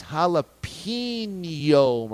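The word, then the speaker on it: jalapeño: Glorious Splat, yes, that's his username, says it's jalapeno, Mraz, not pino.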